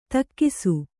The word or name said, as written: ತೆಕ್ಕಿಸು